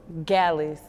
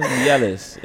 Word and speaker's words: Gyalis: Gyalis. – Yeah, Gyalis. – Okay, I said it right. Yeah, put the Y in there. There's a Y in there, man. Gyalis.